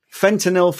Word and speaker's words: fentanyl: Paper six, Simon, fentanyl for rapid sequence induction, the FACT study. Did I say that right? – Yeah, you did, yeah.